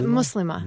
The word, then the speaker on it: Muslima: Are you going to accent the first syllable? Is it Muslima? – It's Muslima.